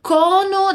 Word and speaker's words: cono: O som é bem aberto, então nada de cono, mas cono. Diz aí, cono.